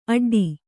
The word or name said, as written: ಆಟಿಡು